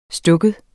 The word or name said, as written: stak